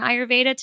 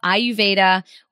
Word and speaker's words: Ayurveda: I cannot wait to dive in and talk all about Ayurveda, which some people by the title of this might think it's pronounced Ayurveda, but the R is silent. Is that correct? – No, it's actually Ayurveda with an R.